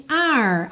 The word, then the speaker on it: are: Percebe que o E do are não é pronunciado, então você tem are, are, como o ar condicionado, ok? Com esse sotaquezinho da língua enrolada.